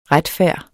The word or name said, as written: retfærd